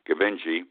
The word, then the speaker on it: Gbinije: His name, difficult to pronounce, G-B-I-N-I-J-E, Gabinji, was interviewed after the Gonzaga game, and he was asked by the reporter, "Why is this happening?"